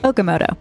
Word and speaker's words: Okamoto: Okamoto.